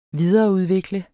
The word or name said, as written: videreudvikle